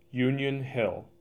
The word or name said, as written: Union Hill